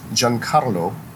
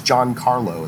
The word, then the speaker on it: Giancarlo: And he was like, you know, but if you can't roll your R's, Giancarlo is fine, is what he said.